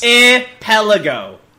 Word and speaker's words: archipelago: Archipelago. Ark? Like Noah's Ark. Pelago.